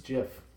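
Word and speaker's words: GIF: I took on the role of the producer for a minute, and Steve Wilhite said that he intended the pronunciation deliberately echoes the American peanut butter brand, Jif.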